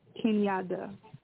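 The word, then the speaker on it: Kinyada: K-I-N-Y-A-D as in David A. Kenyatta.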